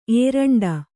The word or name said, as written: ಏರಂಡ